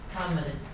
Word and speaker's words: Todmorden: Todmorden.